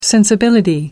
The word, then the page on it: sensibility: American English